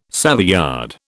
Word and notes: Savoyard: British English

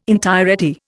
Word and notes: entirety: Indian

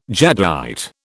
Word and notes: jadeite: British English

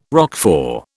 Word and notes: Roquefort: British English

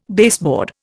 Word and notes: baseboard: Indian

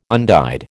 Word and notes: undyed: American English

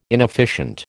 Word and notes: inefficient: American English

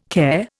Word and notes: care: Indian

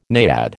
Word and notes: naiad: American English

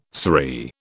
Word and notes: saree: British English

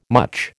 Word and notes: much: American English

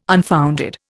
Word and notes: unfounded: Indian

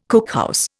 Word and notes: cookhouse: Indian

